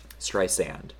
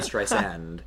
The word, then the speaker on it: Streisand: Sand. Sand like the beach, as she says. This was actually a point of contention because Adele did not say it correctly in her 73 questions with Vogue. – And And people called her out for it because she said Streisand. Zand.